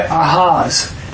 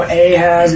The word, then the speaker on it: Ahaz: And it's really Ahaz. So really, anytime it's a Hebrew, A is not a long A, it's a soft A, Ah, instead of A. When we go Ahaz, it's really Ahaz.